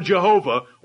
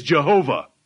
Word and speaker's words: Jehovah: His name reduced to consonants and pointed up with English vowels to pronounce is Jehovah.